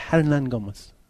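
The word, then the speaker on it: Hernangómez: Hernan Gomez. – The H is silent. Hernan Gomez.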